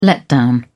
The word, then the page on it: letdown: British English